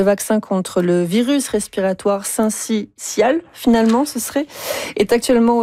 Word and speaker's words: syncytial: Non, selon le dictionnaire Usito, on prononce le syncytial. – Voilà.